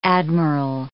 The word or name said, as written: admiral